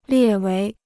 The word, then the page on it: 列为: liè wéi